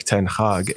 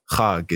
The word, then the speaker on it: ten Hag: Or same thing with Erik ten Hag. It's not Hag.